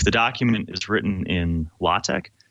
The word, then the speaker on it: LaTeX: So LaTeX, it's pronounced either LaTeX or LaTeX, even though it's spelled latex, it's kind of a good way to describe it is a document processing system.